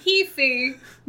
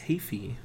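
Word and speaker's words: Heafy: Matt Hafey, not Maddie Healy. – It's pronounced Hafey.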